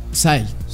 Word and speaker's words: PSY: Psy. Pero se pronuncia Psy. Como Psy.